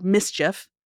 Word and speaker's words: MSCHF: This came up when an art collective called Mischief, that is spelled M-S-C-H-F, filed one of several amicus briefs in a trademark suit we've talked about on the show before.